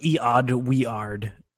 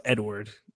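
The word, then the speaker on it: Eadweard: It's like E-odd, we-ard. It's like, no, it's Edward.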